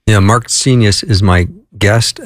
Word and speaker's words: Seignios: I'm an S-E-I-G-N-O-S. I'm a Senius. It rhymes with genius. I'm an S-E-I-G-N-I-O-S.